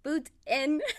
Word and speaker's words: Boutin: So for the people that are going to be listening, my name is Maria Boutin. It's pronounced Boutin. I feel like I always have to specify that because a lot of people give us like different last names.